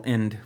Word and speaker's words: Ende: By a gentleman named Michael Ende, which more on him later. Actually, it's E-N-D-E, so maybe it's Ende? – No, it's End. It's German. – Just End. The E is silent.